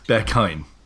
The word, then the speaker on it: Berghain: Berghain. – Right, Berghain.